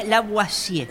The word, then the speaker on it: Lavoisier: Se pronuncia Lavoisier.